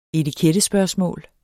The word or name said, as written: etikettespørgsmål